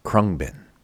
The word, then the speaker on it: Khruangbin: Name is Khruangbin.